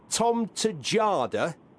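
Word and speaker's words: Tjaarda: Is it Tom Tejada? – Yes, indeed it is.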